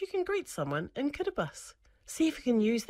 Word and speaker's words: Kiribati: Okay, did you know the word Kiribati is spelt with a T-I at the end of the word? So it looks like Kiribati, but when T-I are together in the Kiribati language, it's pronounced like an S. So it's not Kiribati, it's Kiribati.